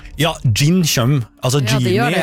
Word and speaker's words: jinn: Gin tonic, ja. Nei, ja, det skrives med jod. J-I-N-N. Gin. Men okay. Også det uttales gin.